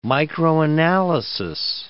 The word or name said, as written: microanalysis